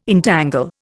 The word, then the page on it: entangle: Indian